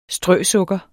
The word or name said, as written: strøsukker